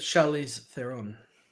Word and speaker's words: Charlize Theron: It's Charlize Theron. That's how you pronounce it.